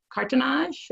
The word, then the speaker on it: cartonnage: She's worked in several frame ateliers, learning conservation framing techniques and the French paper art of cartonnage.